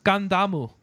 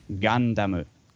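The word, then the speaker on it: Gundamu (ガンダム): So technically, it's made out for it. Gundam is written Gundam. It's a katakana word, but it's written G A N D A M U. – Gundam. – Gundamu. – Yeah. Oh, GA is more of a gas sound, so that's the reason why. Which is the reason why you will hear it pronounced like that. Gundamu.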